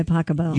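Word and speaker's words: Pachelbel: Canon D by Pasquale. – By Pacabell.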